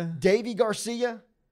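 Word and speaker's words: Deivi Garcia: Davey Garcia, age 21, and he gave up one run in that first inning pitch, and J.A.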